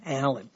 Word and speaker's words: Allyn: And that's spelled, I know I remember it because it was always spelled weirdly. A-L-L-Y-N, Allen. Kirk Allen.